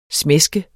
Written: smæske

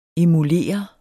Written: emulere

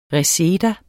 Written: reseda

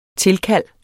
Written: tilkald

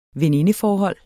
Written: venindeforhold

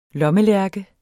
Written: lommelærke

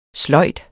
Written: sløjd

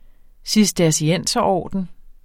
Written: cistercienserorden

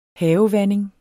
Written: havevanding

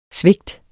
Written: svigt

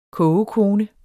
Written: kogekone

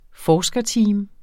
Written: forskerteam

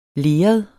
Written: leret

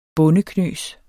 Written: bondeknøs

